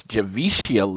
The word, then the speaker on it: Javicia: I met Javika Leslie. I realized I was saying her name wrong for the long time. It's Javicia Leslie.